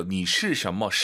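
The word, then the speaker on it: 你属什么: 발음 잘하셔야 돼요. 니슈什么? 시 쓰면 안 돼요.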